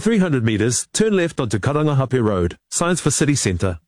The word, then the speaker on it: Karangahape: That was TVNZ's Māori Issues reporter Tianiwa Huri Hanganui driving around Auckland and unimpressed with the navigation voice on the Google Maps app mispronouncing Karangahape Road and other places with Māori names.